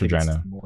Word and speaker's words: Regina: I think it's Regina. – It's Regina. – I think it's the more stupid way to pronounce it.